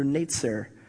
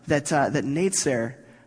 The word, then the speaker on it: netzer: And it's so interesting that netzer is very much exactly how you would pronounce the word Nazarene in the sort of borrowed Greek that Matthew's writing in. So when you hear netzer, you kind of also hear Nazarene.